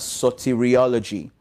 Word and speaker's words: soteriology: But also from a theological viewpoint, when you're looking at the foundational doctrines, there is a branch of theology, basically known as soteriology. Okay, it's known as soteriology. So it's spelled S-O-T-E-R-I-O-L-O-G-Y.